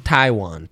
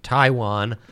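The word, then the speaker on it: Taijuan: Taiwan. – Taiwan.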